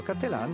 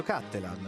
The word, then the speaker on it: Cattelan: Maurizio Cattelan o Maurizio Cattelan. Naturalmente l'origine veneta padovana o l'adozione statunitense giocano nella pronuncia del nome che viene presentato dai media da noi con entrambe le possibili pronunce.